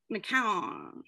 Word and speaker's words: McCown: And also, I've been pronouncing your last name wrong. McCown. – Yeah, I've been saying McCown, but it sounded like a guitar riff. – McCown. Yeah, like ice cream cone.